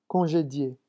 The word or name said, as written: congedier